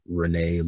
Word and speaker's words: Rene: Renee Lumain. Name is spelled R-E-N-E. Last name L-U-M-E-N-E.